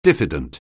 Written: diffident

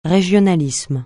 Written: régionalisme

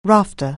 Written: rafter

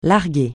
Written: larguer

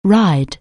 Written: ride

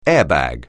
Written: airbag

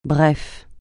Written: bref

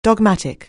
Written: dogmatic

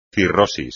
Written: cirrosis

